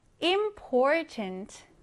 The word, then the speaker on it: important: 자, 이번 단어는 important. important. 무슨 뜻일까요? 중요한입니다. 자, 얘를 읽을 때는요. 강세를 이렇게 가운데 넣어주세요. important. important. 이렇게요. 자, 그러면 우리 이 단어도 같이 한번 써보겠습니다. important. 써볼게요. I am P-O-R-T-A-N-T 길이가 조금 있지만 여러분이 스펠링 꼭 기억하고 있어야 하는 단어입니다.